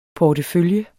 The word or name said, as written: portefølje